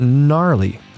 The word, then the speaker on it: gnarly: And that It's spelled weird, but we say it gnarly. – Gnarly means amazing. – Yeah. It means awesome. – Yeah. – Often used by surfers. So it's spelled like G-N-A-R-L-Y.